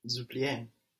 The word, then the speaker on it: oublient: Il nous oublie. ENT. Don't pronounce the ENT.